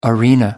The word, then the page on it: arena: American English